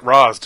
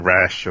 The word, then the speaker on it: Ra's: So you pronounce it Ra's, do you? – Yeah. Just because I read it before I saw the TV show. – Okay. – So you can call him Rash or Rock. Or Raz.